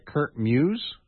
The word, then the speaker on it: Kurt Muse: This dealt with a fellow by the name, if I'm pronouncing his name right, Kurt Muse? – Correct.